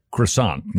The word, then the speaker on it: croissant: which would make it a croissant, not an almond croissant. – You're saying it wrong. – Croissant. – That's better.